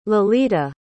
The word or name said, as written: Lalita